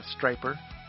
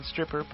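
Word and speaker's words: Stryper: En nuestra frecuencia social tenemos a Striper, que algunos pronuncian stripper, pero no es eso.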